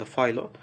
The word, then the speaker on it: Philo: Ee, sanırım doğru telaffuz İngilizce'de Philo. Ee, fakat eğer ki antik Yunanca bir isimse ya da genel olarak Yunanca bir isimse Philo diye telaffuz edilmesi daha doğru olur.